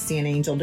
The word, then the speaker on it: San Angel: The Japanese quick serve and the La Cantina San Angel. I don't know if I said that right at all. I'm sorry. – Everyone always says San Angel to me, so I say that's good.